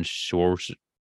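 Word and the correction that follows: Skowronek: or whatever. – Skoronic or some shit, yeah. – I was way off.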